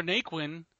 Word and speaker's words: Naquin: And then Tyler Naquin,